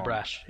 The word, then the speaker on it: Abrash: Yeah, Abrash, he's now... Is that how you say his name? I could be wrong. – Abrash, yeah.